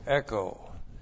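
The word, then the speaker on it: echo (ἔχω): Then we have the verb echo. E-C-H, long O, echo.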